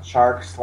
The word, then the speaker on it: Tjarks: I'm at Jonathan Charks.